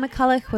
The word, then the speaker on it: McCulloch: McCulloch? Or sometimes, yeah. You can't say Locke. – McCulloch.